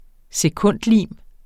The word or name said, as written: sekundlim